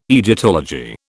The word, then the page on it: Egyptology: British English